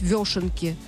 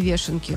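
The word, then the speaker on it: вёшенки: И он говорит, что узнал, что правильно говорить «вешенки», а не «вешенки». – Правда? – Как мы, да, через «ё».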